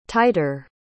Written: tighter

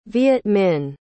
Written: Vietminh

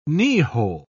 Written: kneehole

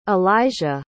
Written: alayja

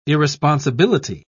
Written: irresponsibility

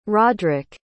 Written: rodric

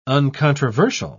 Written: uncontroversial

